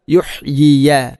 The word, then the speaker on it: يحيي: യുഹ് യാഗിന് കസർ ഒന്നാമത്തെ, യുഹ് യി രണ്ടാമത്തെ യാഗിന് ഫതഹ്, യുഹ് യിയ.